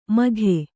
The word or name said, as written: ಮಖ